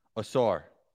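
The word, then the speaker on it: Ausar: How do you say his name? – Asor. – Asor? – Yes.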